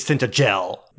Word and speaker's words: Tintagel: And we note regarding pronunciation, oh, he's the one that said it's Tintagel. – Okay. – No, you're right. It's soft G before an E.